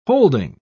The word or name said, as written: holding